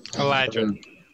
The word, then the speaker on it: Eladrin: Elodrin. – Eladrin. – That word.